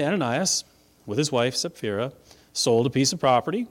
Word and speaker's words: Sapphira: Now, I know most people call her Sapphira. You know me, I like Greek. Her name is Sapphira. That first P is pronounced, but you can call her Sapphira if you want to.